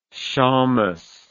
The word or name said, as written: shommus